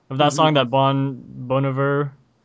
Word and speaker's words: Bon Iver: Oh, that's how you say it? – Bon Iver, yeah, it's not Bon Iver.